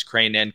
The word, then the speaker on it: Crain: It's Crane & Company, C-R-A-I-N.